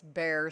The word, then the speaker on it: Bexar: mainly because the numbers in San Antonio slash Bexar County, that's bear sounding like an animal, but it's spelled Bexar.